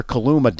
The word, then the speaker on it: Kaluma: And Kaluma, do I got his name right? – You got it.